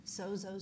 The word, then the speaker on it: sozo: The definition for saved is the word for saved in the Greek is S-O-Z-O, pronounced sozo.